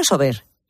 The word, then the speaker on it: Sober: ¿Se dice Sober o Sober? – Sober, lo he dicho bien, digo, a ver si lo estoy diciendo mal.